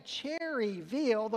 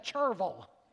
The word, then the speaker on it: Cherryville: Now, if you're from Gaston County, you don't say Cherryville the way it's spelled. You say Cherville.